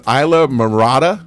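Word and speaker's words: Islamorada: And here's another one. Isla Mirada. Did I say that right? – Yeah.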